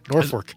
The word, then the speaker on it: Norfolk: Especially the fact that it's spelled with an L but pronounced with an R. Norfolk.